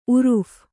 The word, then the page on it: ಉರುಫ಼್: kannada